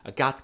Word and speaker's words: Agathe: Nicholas walks off in a huff, and then the phone starts ringing from inside, and Agathe which is the French pronunciation, apparently, of Agatha. A goth comes out.